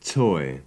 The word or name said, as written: toy